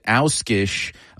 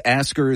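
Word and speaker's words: Ásgeir: The Icelandic singer has said that in his homeland they pronounce it Auskish, but he says Ask her is fine with Ferris Wheel.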